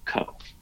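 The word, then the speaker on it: ko: But the last part I usually pronounce like K-O, Co.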